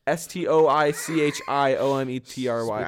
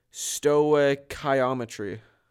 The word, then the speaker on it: stoichiometry: Stoichiometry.